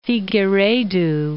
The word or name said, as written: Figueiredo